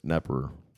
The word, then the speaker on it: Knepper: H-E-R-B. – It's silent. Herb. Just like how my last name has a silent K at the beginning, but not on my social media. It's secret. – Mm-hmm. – What? – I don't think anything really silent. – It's Knepper, but it's Nepper.